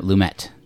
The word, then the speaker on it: Lumet: I like how you pronounce it Lumet and not Lumet. – Yes, it's Lumet.